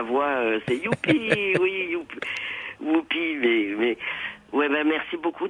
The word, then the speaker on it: Whoopi: Et puis je vous le dis pour pas que, parce que c'est il y a beaucoup, on dit souvent Youpi, mais c'est Whoopi.